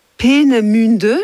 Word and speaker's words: Peenemünde: qui s'appelait Peenemünde, je crois que c'est comme ça qu'on prononce. – Tout à fait ça.